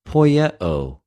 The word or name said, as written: ποιεω